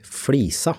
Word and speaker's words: Flisa: Eller med tjukk L, så blir det riktig Flisa.